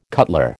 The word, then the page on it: cutler: American English